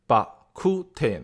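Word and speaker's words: Bak Kut Teh: 你看“ 肉骨茶” 这三个字，为什么在闽南话或者潮汕话中，它的发音是把 ku te 呢？啊，是吧？你看肉啊叫把，那这个骨叫 ku 啊，茶叫 te，只这三个字里头只有骨的发音和这个普通话最近，肉叫把啊，这个茶叫 te，我就仔细又研究了一下。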